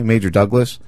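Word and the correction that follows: Marjory Stoneman Douglas: School? Is that what it is? – I think it's Marjorie Stoneman Douglas School.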